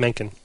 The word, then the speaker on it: Mencken: Yes, Mencken.